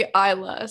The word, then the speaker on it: Eillah: Yeah, that would be Isla. So E-I-L-L-A-H.